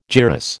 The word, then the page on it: gyrus: American English